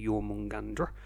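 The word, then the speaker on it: Jörmungandr: We can also see in the Norse myths with Jormungandr, or Jormungandr, just to annoy people who like to say it properly, Jormungandr.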